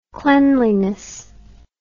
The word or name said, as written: cleanliness